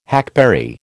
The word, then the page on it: hackberry: American English